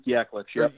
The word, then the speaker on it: Yaklich: Now, one of the interesting inside-the-matchup type deals is what Luke Jelich, is that how you pronounce his name, the assistant coach for Michigan that spent time at, where was he, Illinois State? – Yeah, Luke Yaklich.